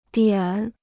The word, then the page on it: 点: diǎn